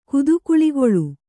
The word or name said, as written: ಕುದುಕುಳಿಗೊಳು